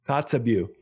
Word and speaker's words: Kotzebue: He is a doctor at the hospital and emergency room in the Arctic community of Kotzebue. Is that correct? Am I saying it right? – Kotzebue.